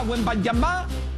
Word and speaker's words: Wembanyama: Aquí está Shai Gilgeous-Alexander, no llegaba a Wembanyama, porque así se pronuncia de manera correcta, por cierto, Víctor Wembanyama, pero en Estados Unidos ya lo bautizaron como Wembanyama.